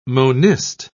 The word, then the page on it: monist: British English